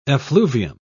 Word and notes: effluvium: British English

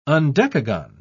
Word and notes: undecagon: British English

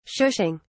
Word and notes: shushing: British English

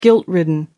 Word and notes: guilt-ridden: American English

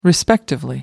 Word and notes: respectively: British English

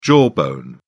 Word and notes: jawbone: British English

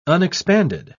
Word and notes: unexpanded: British English

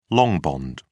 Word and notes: long bond: British English

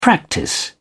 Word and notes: practice: British English

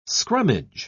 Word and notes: scrummage: British English

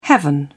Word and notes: heaven: British English

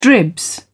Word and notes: dribs: British English

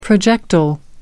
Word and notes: projectile: American English

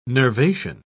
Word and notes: nervation: British English

